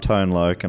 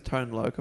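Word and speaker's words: Tone Loc: Because it's spelled Tone, T-O-N-E. – No, I think it's Tone Loke. I've heard that. – I'm Tone Loke.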